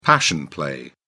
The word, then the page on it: passion play: British English